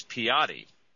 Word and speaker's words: Piety: And the next time you're talking to her, apologize to her on my behalf, because I was pronouncing her name yesterday, Piety. It's Piety. – Piety. – It is Piety, P-I-E-T-Y, which if you're a theologian, you would say Piety. But in fact, her name is pronounced Wanda Piety, and she is based in Los Angeles, correct? – She's in North Los Angeles, correct.